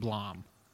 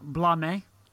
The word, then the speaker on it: Blame: Yes, and it's spelled Blame, but it's pronounced Blom. So you guys know. – Is it not Blame? – No.